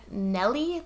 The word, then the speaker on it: Nuell: Elizabeth Marie Nelly, they had a phonetic pronunciation, that's how they said, but it's spelled N U E L L. So, I'm just going to say Elizabeth Nelly.